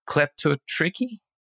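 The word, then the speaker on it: kleptotrichy: The word is, and please, English speakers, pronounce it for me, kleptotricky. – That sounds right. – Sounds right. How would you say it, Molly? – I think that's perfect.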